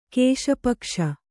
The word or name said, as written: ಕೇಶಪಕ್ಷ